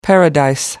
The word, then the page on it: paradise: American English